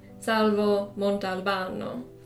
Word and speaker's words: Salvo Montalbano: The Montalbano detective series centers around Salvo Montalbano, or said with the correct pronunciation, Salvo Montalbano.